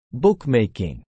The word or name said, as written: bookmaking